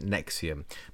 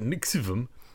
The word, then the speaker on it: NXIVM: Now, Nixivum is spelled NXIVM. Actually, it says here that you're supposed to pronounce it Nexium, but I think it's a lot more fun if you say it Nixivum.